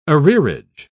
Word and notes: arrearage: British English

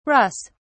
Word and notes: russ: British English

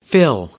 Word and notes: fill: American English

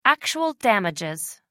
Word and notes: actual damages: American English

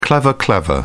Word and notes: clever clever: British English

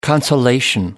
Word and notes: consolation: American English

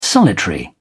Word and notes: solitary: British English

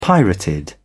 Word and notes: pirated: British English

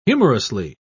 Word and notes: humorously: British English

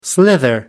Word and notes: slither: American English

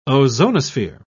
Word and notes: ozonosphere: British English

